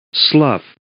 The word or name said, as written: slough